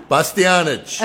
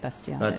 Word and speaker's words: Bastianich: did I say it wrong? – Bastianich. – Okay, Bastianich.